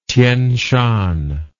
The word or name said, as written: Tien Shan